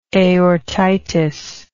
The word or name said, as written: aortitis